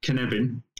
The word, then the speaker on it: Cynefin: It's a Welsh word, so it's spelt differently to, I would say it, but Cynefin. I can't spell it, but it begins with a C.